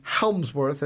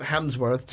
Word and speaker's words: Hemsworth: But because he wears that beautiful thing that you're putting on, I think it should be Helmsworth and not Hemsworth. So I'm sorry, Chris, that I keep mispronouncing your name.